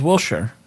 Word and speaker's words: Wilshire: But now I told him, like, no, it's pronounced Wilshire.